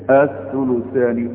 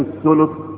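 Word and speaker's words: الثُّلُث: وهتجد بقى الايات اللي القرآن الكريم تتكلم هتقول الثلث والربع والثمن. في ناس تيجي تنطقها الثلث والربع والثمن بالتسكين كده. لكن الثلث كلها ضمة. الربع كلها ايه ضمة.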